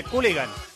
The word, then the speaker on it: Culligan: La penúltima camiseta que un oyente va a ganar en este programa de Cooligan, los amigos de Cooligan, escrito con dos O's y dos L's se escribe Culligan pero se dice Cooligan.